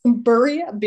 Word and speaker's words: birria: I do not know how to pronounce this word. So, birria, Bria. – birria, it's spelled BIRRIA.